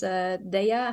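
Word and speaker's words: Daiya: and this is Daya, I think you pronounce it, mm-hmm.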